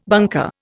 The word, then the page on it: bunker: Indian